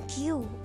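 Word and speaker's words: Q (九): Hachi Q sembilan Q bukan ku ya Q Oke,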